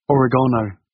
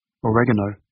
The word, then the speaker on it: oregano: Oregano, or as the Americans say, oregano, oil, and turmeric.